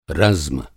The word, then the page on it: रज़्म: Hindi